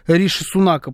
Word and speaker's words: Риши Сунак: Кстати, я вчера обещал вам узнать, как Риши Сунака правильно произносит BBC. Риши на вторую «и» ударение. Сунак. Вот так правильно звучит имя этого человека.